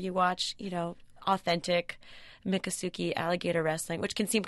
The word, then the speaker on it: Miccosukee: Miccosukee Indian village.